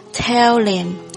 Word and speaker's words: tell him: 这一句有几处需要注意的地方，首先第一个 and 的略读， then 和 I 连读成 then I had 的略读， tell 和 him 有一个击穿的现象，连读成 tell him， h 不发音。